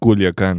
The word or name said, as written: Culiacan